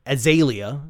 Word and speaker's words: Azalea: This time, you had DNCE, Iggy Azalea, or Azalea, as it's pronounced correctly, and you had Coldplay.